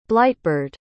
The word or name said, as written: blightbird